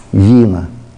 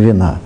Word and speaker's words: Вина: И это уже будет закон смещения вина. Ударение на И, а не вина.